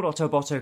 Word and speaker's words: Otoboto: It's called Otoboto because it's similar to the way the Japanese pronounce the word Autobot.